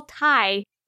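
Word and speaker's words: Tigh: He was also Michael Hogan as Colonel Saul Teague in Battlestar Galactica. Ty. Sorry if I pronounced that wrong. Oh, Colonel Saul Ty in Battlestar Galactica.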